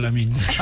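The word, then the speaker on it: Lamine: ¿Lamín lo digo bien o cómo? – Sí, no, muy bien.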